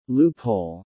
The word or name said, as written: Loophole